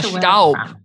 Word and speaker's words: Staub: Staub, which actually in German, Staub means dust, which is very funny.